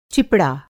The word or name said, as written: चिपड़ा